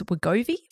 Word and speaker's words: Wegovy: So Novo Nordisk is the maker of Ozempic and another drug designed solely for weight loss, Wegovy. Is that how you say it? – Yeah.